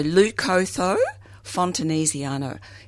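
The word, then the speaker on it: Leucothoe fontanesiana: Yeah, so Leucotho fontanesiana. If you want me to spell that, it's L-E-U-C-O, so Leuco, T-H-O-E, and then fontanesiana, F-O-N-T, A-N-E-S-I-A-N-A.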